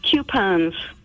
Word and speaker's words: coupon: Coupons. C-O-U-P is pronounced coop.